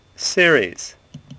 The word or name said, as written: Ceres